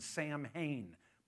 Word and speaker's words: Samhain: It's spelled S-A-M-H-A-I-N, Samhain, but it's pronounced Samhain. Catch the last syllable of that, een?